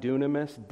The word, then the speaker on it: dunamis: Sometimes the Greek word that's used for power in the New Testament is the word that sounds like the word dynamite. Dynamite, dunamis, dynamite, but that's not the word here.